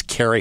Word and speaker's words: Cary: It's spelled C-A-R-Y, like Gary with a C, because there's a lot of different ways to spell that name. But it's Carrie Hall.